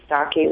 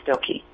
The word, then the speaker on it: Stockey: If it was like two generations ago, you would have been exactly right. My Norwegian last name, Stockey, was largely pronounced that, but... we have now since advanced to saying Stokey.